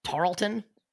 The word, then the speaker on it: Tarleton: So Gonzaga follows up the loss to Duke by only beating, I don't even know if I'm pronouncing this correctly, Tarleton? – Yeah, Tarleton State.